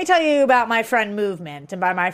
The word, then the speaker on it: MVMT: So it's spelled MVMT, but it's pronounced Movement.